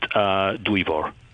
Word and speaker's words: Dwyfor: Two simple syllables. It's pronounced Dwyfor. – Dwyfor. – Yes, Dwyfor.